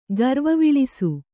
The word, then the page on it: ಗರ್ವವಿಳಿಸು: Kannada